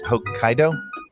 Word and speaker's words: Hokkaido: How do you pronounce this? Hokkaido. It's Hokkaido. He's irritated with me. It's in Japan.